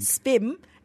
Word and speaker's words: SPIM: SPIM, it rhymes with brim.